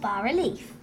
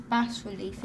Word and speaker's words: bas-relief: It's spelled B-A-S, bas relief. Isn't that how you say it? – Lots of people think that, but actually it's a French word, so we have to say bas-relief.